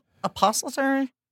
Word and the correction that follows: epistolary: You mean epistolary, letters? – Sorry, another word I mispronounced.